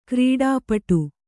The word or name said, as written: ಗರುಡಪೀಠ